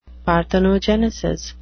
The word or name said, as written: parthenogenesis